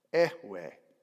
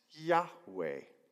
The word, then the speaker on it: Yahweh: I am, in Hebrew, is pronounced Ehweh. He is, in Hebrew, is pronounced Yahweh. Ehweh is I am, Yahweh is he is.